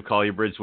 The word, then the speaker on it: colubrid: I was looking at a whole bunch of colubrids, which I talked to Dr. Warren Booth, or he sent me an email. He says, "I do pronounce it correctly. It is colubrid."